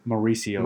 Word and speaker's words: Mauricio: How do you say it? – Mauricio. – Mauricio, thank you.